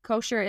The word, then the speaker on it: Koecher: Koecher is spelled K O E C H E R.